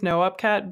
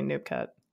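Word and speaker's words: noopkat: The correct way to pronounce it is NoUpCat, but I'm lazy because that sounds like two syllables. So a lot of the time I just say NoopCat.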